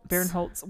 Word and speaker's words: Barinholtz: Barinholtz.